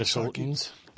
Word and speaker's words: Minnesotans: I played with loads of Minnesotians in college. – Minnesotans? – Minnesotans, is that how you say it? – Yeah. They got to say it like they do, Minnesotans.